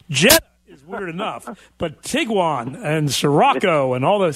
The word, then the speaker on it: Tiguan: I think it's spelled T-I-G-U-A-N. – Tiguan.